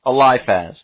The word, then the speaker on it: Eliphaz: And I'm sure I'm pronouncing that name wrong. Eliphaz? Yeah, Eliphaz.